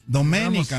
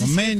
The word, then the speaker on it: Domenica: Domenica, Antonio, Domenica, con acento en la E, Antonio. – Ah, sí, Domenica.